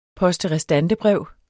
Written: poste

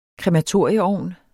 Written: KREMATORIUM

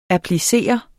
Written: applicere

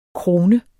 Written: krone

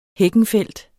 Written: HEKKENFELDT

